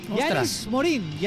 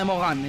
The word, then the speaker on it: Yannis Morin: Se dice como se lee, Yannis Morin, Yannis Morin, así. – ¿Sí? – Nada de pronunciación a la francesa, Yannis Morin. – Nada de Mogán, entonces.